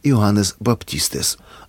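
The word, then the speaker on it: Иоаннес Баптистес (Ἰωάννης Βαπτιστής): Все Евангелия написаны на греческом языке, а по-гречески Иоанн Креститель произносится «Иоаннес Баптистес».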